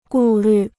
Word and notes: 顾虑: gù lǜ

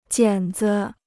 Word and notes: 剪子: jiǎn zi

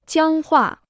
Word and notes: 僵化: jiāng huà

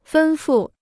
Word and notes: 吩咐: fēn fù

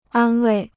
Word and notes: 安慰: ān wèi

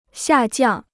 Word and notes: 下降: xià jiàng

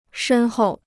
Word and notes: 深厚: shēn hòu

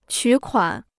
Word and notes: 取款: qǔ kuǎn

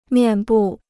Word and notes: 面部: miàn bù

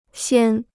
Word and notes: 先: xiān

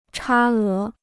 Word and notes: 差额: chā é

